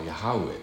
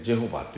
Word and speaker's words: ヤハウェ: それ無理やり読んでエホバって読んだのがまあ、間違った読み方なんだけども、まあ、まあまかり取ってるわけですよ。エホバになってるわけですけども、エホバっていうのは本来の聖書のどこにもなくて、本来の正しい発音はヤハウェっていうことですね。ヤハウェ、ヤウェの神、まあそれが正しい読み方なんですけども、 Y のところ J というふうにもう読めるんで、ジェホバとイエホバというふうに、まあ、読んだというですね、まあ YHWW の方は V にも読めるんでジェホバとこういうふうに読んだんですね。